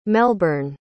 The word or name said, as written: melbourne